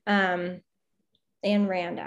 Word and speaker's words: Ayn Rand: Ayn Rand, actually, not Rind. Said that wrong.